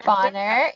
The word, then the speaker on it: Bonar: I'm here with Allie Bonar. – Bonner, yeah.